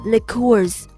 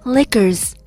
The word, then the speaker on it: liqueurs: 从 New York accent 纽约地方口音回归到我们的主题 beverages，绕了这么大一个弯子，其实就是想跟大家说为什么 John 的 liqueurs 跟我说的 liquors 都是一个单词，但是发音有这么大的不同。 liquors 重音发到了 O 上面就成了 liqueurs。